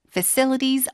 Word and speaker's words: facilities: Facilities are getting upgraded. – Facilities 발음이요. – Facilities. – C의 강세니까 fa가 아니라 fu죠 fu. Facilities.